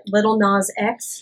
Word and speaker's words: Lil Nas X: Old Town Road, the remix, by Lil Nas X. Is that how it's pronounced? – Yes, it is.